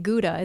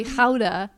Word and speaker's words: Gouda: Yes, and that's one thing I've discovered is we've been mispronouncing Gouda. It's actually Gouda, and we are here really close to the home of Gouda cheese.